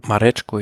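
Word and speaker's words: Mareczko: Nur geschlagen von Mark Cavendish und Jakob Marezko. Irgendwie so wird er wahrscheinlich ausgesprochen. – Marechko, ja.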